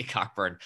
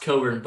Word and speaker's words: Cockburn: I keep on want to say Cockburn. – Well, it's pronounced Coburn, but it is spelled.